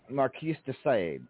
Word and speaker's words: Marquis de Sade: Marcus de Sade, is that how you pronounce that one? – Marquis de Sade.